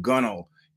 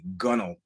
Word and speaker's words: gunwale: a song called Gunnel. And yes, that is how you pronounce a word that is spelled G-U-N-W-A-L-E, Gunnel, the last song on the record.